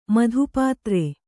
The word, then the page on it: ಮಧುಪಾತ್ರ: Kannada